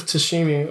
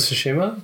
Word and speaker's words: Tsushima: Because I watched Ghost of Tsushima. Was that? Yeah. I think that's how you say it, probably. – Ghost of Tsushima? – Yeah. – I think that the T is silent.